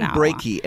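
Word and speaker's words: Breakey: I believe it's Brakey. His first name is Norman, so maybe we'll just call him Norman. – Norman Brakey, as in heartbreaky.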